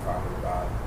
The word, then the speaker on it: Prabhupada: And I think I read a couple of Prabhupada, I don't know if I pronounce his name.